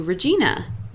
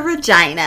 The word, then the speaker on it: Regina: But basically, it rhymes with vagina. So, I've been to Regina.